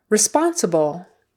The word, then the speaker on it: responsible: Okay, responsible, responsible, responsible is how we usually pronounce it in casual conversation. Responsible, could probably get away with that. Responsible, the accent is on the second syllable.